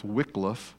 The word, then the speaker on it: Wycliffe: And so the Wycliffe translation, and by the way, it is pronounced Wycliffe, that Y gets an I sound, becomes the first English translation into a more contemporary English.